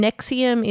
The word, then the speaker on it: NXIVM: I have been listening to a Canadian podcast, which is called Uncover Escaping Nexium. And Nexium is spelled NXIVM.